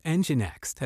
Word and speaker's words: Nginx: Nginx, pronounced Nginx, has developed as a popular web server solution for many developers and admins.